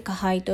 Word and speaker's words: 加配: 加配っていうのは加えるという字に配慮の配と書いて加配と読みます、